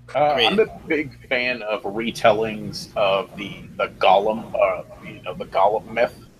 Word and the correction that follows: Golem: Or Golem, I should say. I always mispronounce it.